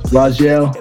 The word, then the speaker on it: Raguel: I'm Rajel, pronounced Rajel, R-A-G-U-E-L.